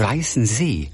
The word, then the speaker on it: Weißensee: Weißensee. – Ja. – Aber warum wird das wohl hinten betont? – Das weiß ich nicht.